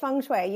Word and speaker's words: feng shui: So why did you decide, or what was it that helped you decide to go into, is it, I always pronounce it feng shui, but is it feng shui? – No, it's feng shui. You pronounce it correctly.